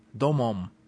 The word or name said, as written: domum